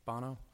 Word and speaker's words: Bono: Apparently, about a year ago, the rock singer from U2 group, Bono? Is that how you say his name? Anybody familiar with pop culture? Bono? I'm getting a thumbs up from Lance. That's good.